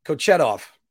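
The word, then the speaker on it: Kochetkov: oh, because I wanted to make sure I pronounce it right. Kochetov. Peter Kochetov.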